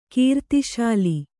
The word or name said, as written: ಕೀರ್ತಿಶಾಲಿ